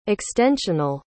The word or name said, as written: extensional